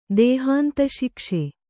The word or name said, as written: ದೇಹಾಂತಶಿಕ್ಷೆ